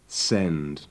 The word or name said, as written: send